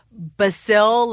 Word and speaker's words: basil: And she also told me I was pronouncing basil wrong, that it's actually basil, like basilica, but that one I'm not going to change.